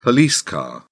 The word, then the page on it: police car: British English